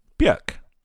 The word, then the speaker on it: Björk: Isn't it Björk? – Björk. Because it has the two things above the O. – Also, how do you spell W-O-R-K? – It's called an umlaut.